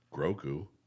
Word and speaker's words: Grogu: Grogu. Is that right? Did I say it right? – You got it.